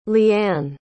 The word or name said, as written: leanne